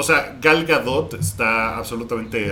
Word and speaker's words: Gal Gadot: No, no, no, no, que por cierto eh, se pronuncia Gal Gadot.